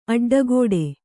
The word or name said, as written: ಅಡ್ಡಕಟ್ಟು